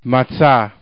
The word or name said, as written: matzah